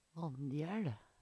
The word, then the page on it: AnjæL: Numedalsmål